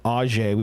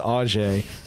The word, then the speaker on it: Auge: And it's that's that weird, like, it's like a pillar, and then it goes to a Wahlberg and Auge. We've been saying it wrong. Not Aug. It's Auge.